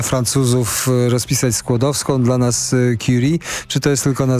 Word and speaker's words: Curie: Bardzo ładnie pan Curie mówi, bo ja zauważyłem, że Curie to jest taka najpowszechniejsza wymowa, ale to chyba powinno być jednak tak, jak pan mówi. – Obawiam się, że chyba tak. No, jednak też się staramy właśnie nie tylko promować kolejność Skłodowska-Curie, tylko też wymowę właśnie nie Curie, tylko Curie. To jednak tak.